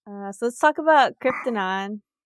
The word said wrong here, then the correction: Kryptodon: Just kidding. I don't even think I said it right. – Kryptodon. – Kryptodon. Yeah.